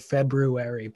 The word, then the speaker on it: February: I don't know if I've expressed this to you every year around this time, but I really appreciate your pronunciation of the word February, putting the emphasis on the R.